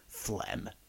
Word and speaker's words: phlegm: Phlegm. And we'll spell it with a silent G.